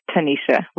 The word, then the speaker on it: Tanisha: It's Tanisha with a T.